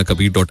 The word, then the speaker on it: कबीर: Please get in touch with us, और आप मुझसे भी जुड़ सकते हैं, दोहा वाला कबीर FM107, कबीर की स्पेलिंग रहेगी KABIR.